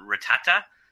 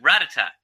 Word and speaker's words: Rattata: They pronounced the Pokemon Ratata, Mm-hmm. – And uh, no, it's Ratata. It's Ratata. Watch the Pokemon show. – It's I thought it was Rattata. See, oh, see, more, more like a D sound.